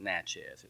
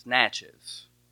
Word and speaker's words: Natchez: I was calling it Natchez, but I was told you don't pronounce it Natchez, it's Natchez.